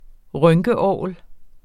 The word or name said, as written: rynkeorgel